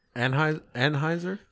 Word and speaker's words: Anheuser: Anheuser? – Yeah.